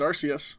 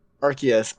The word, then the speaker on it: Arceus: So I actually learned why the official pronunciation for Arceus is Arceus, and the reason why, and I'm talking not U.S. English, but England English, because Arce. – Oh. – The word arse in England.